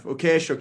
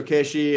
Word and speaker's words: Ukheshe: Ukheshe, is that close enough? Good pronunciation? – Close enough. Ukheshe, Ukheshe.